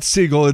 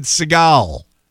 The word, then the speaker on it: Seagal: "Oh, no, no, it's not Seagal. It's, he pronounces it Seagal."